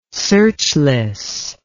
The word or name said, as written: searchless